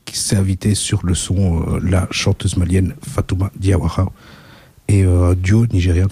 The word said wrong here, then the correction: Fatoumata: T'as oublié une syllabe à la fin, quand même. – Diawara. – Fatouma. – Fatoumata Diawara.